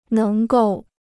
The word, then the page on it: 能够: néng gòu